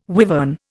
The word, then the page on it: wivern: Indian